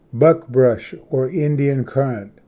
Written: ᎬᏃᏌᏍᏗ ᏗᎪᏢᏙᏗ

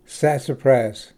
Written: sassafras

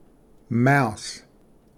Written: ᏥᏍᏕᏥ

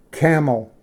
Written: ᎨᎻᎵ

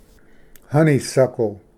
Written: ᏩᎴᎵ ᎤᏂᏣᎵᏍᏗ

